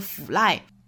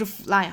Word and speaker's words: fly: 还有像说 fly 的时候说 fly，其实不是 fly 哈，应该是 fly fly。